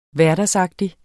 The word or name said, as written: HVERDAGSAGTIGT